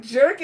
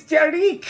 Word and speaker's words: Jer'ik: Yeah, Kirilla, you always pronounce that wrong. It's not jerk, it's Jer'ik.